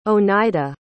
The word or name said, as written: oneida